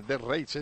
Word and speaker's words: Death Race: De Arrace. ¿Cómo es? ¿De Arrace? ¿Cómo se dice? ¿Cómo se dice? – Death Race, supongo. – Ah, vale, Death Race, ese.